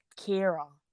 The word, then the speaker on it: Ciara: But I don't know, because it's spelled C-I-A-R, C-I-A-R-A, but pronounced Kira.